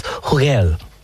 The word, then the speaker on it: Hugel: But the lead artist is, he's French, and it's Hugel.